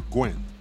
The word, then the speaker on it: Nguyen: No, well, I was going to say if, like, the Vietnamese Gwen, N-G-U-Y-E-N, the N is silent, so you just say Gwen.